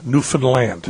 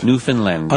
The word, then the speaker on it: Newfoundland: It rhymes with understand. Newfoundland. – Newfoundland.